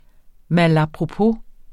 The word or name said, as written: malapropos